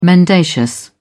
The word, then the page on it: mendacious: British English